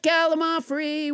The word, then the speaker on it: Gallimaufry: Let's never forget Gallimaufry, which I pronounced wrong until nobody James Hyder corrected me.